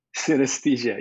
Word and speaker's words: synesthesia: synesthesia, yes.